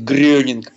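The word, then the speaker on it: Groening: Я вообще, вот, в принципе, все, что делал Мэтт Гроунинг, было бы, кстати, правильно говорить Грёнинг, потому что это немецкая фамилия, которая как бы так трансли...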